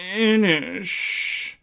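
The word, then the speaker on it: Inis: But anyway, it's spelt Innis. People say Innish, and if you say Innis, they get very, very snooty about it. Oh, don't you know? It's pronounced Innish.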